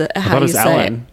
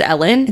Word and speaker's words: Elin: She discovered Elin Hildebrand on this trip. Is that not how you say her name? I thought that was Ellen. – Ellen. – Oh, is it just Ellen? – It's Ellen.